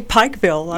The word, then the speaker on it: Pikeville: Can we say Pikeville? – Mm-hmm. – Pikeville? – You can say it any way you like. – How do you say it? – Yeah, these days I say Pikeville. I'm sure growing up I said Pikeville.